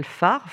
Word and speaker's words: PHARE: Alors, l'organisme de formation et d'édition s'appelle PHARE. PHARE, c'est un acronyme, hein, ça s'écrit PHARE, comme le phare, mais ça veut dire psychologie, humanisme, aidance, recherche et éducation.